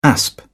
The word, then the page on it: asp: British English